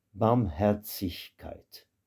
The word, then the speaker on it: Barmherzigkeit: Le Z de ce mot-là, le Z se prononce comme TS, tsich. Barmherzigkeit.